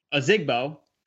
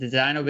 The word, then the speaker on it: Ozigbo: If it was up to me, I still prefer Divino Ozigbo. That's how you pronounce his name. Divino Big Ozigbo.